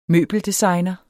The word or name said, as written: møbeldesigner